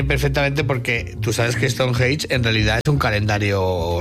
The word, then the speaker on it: Stonehenge: Eres la persona que mejor he escuchado pronunciar Stonehenge. – Stonehenge. – Se dice Stonehenge.